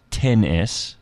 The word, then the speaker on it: iPhone XS: So Apple's new iPhone XS, which is pronounced 10S, has a 5.8-inch screen and will be sold at the starting price of $999.